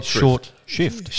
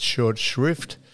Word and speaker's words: shrift: Don used to say short shift. – Shift, yeah, short shift, but it's short shrift, as in a blue riband event is not a blue ribbon event, it's a blue riband event.